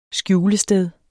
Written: skjulested

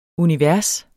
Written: univers

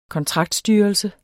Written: kontraktstyrelse

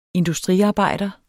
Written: industriarbejder

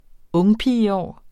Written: ungpigeår

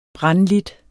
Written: brandlidt

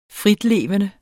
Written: fritlevende

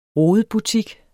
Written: rodebutik

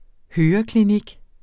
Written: høreklinik

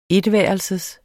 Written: etværelses

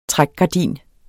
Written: trækgardin

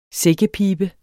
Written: sækkepiber

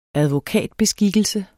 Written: advokatbeskikkelse

